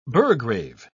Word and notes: burgrave: British English

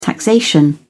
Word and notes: taxation: British English

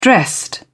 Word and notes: dressed: British English

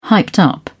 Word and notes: hyped up: British English

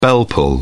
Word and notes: bell pull: British English

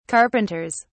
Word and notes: carpenters: British English

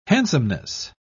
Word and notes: handsomeness: British English

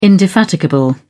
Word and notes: indefatigable: British English

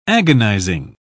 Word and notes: agonising: British English

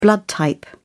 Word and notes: blood type: British English

